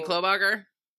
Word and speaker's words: Klobuchar: I'm interested in Amy Knobloch. But I'm concerned about the stories that she was the Leona Helmsley of her. – Amy Klobuchar? – Yeah, that's it.